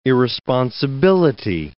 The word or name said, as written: irresponsibility